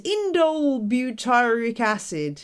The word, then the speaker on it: indole butyric acid: is indole butyric acid, or IBA.